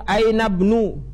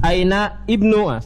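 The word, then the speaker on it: aina bnu (أين ابن): Demikian ibnul mudaris, ketika di depan dibacanya ibnul mudaris fil fasl, tapi ketika disambung aina bnu, jangan antum baca aina ibnu, salah. Aina bnu al mudir, ini hamzah wasolnya.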